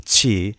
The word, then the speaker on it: qi: And in Chinese, particularly Mandarin Chinese, it's pronounced, I'm sorry, I don't have the tones, but qi. It sounds a lot like ch, but it's like, it's like a different part of the tongue that hits the palate. But anyway, that's the sound in Chinese.